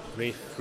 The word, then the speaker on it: Ralph: He doesn't like being called Ralph, does he? Fucking call me that, you fucking commoners. – Give me my posh name. – It's spelled with Ralph, though, isn't it? – It's spelled with Ralph, yeah.